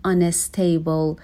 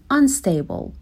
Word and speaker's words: unstable: به تلفظ unstable دقت کنیم، نگیم unstable، یه ای بهش اضافه نکنیم، unstable به این صورت.